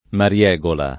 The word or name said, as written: mariegola